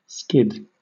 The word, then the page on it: skid: British English